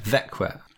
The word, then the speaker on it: Växjö: spelt V-A-X-J-O, but it's pronounced Växjö.